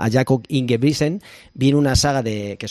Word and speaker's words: Ingebrigtsen: Y para ello nos vamos a fijar en la figura de Jakob, a ver si lo digo bien, se dice Ingebrigtsen. – Ingebrigtsen, sí.